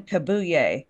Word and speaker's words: Kabuye: His name's Stephen Kabuye. And I got a check on like how to pronounce that. So that should be right. – K-A-B-U-Y-E. So yeah. I think it's pretty Kabuye. Darn close.